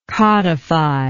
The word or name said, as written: codifier